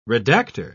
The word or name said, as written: redactor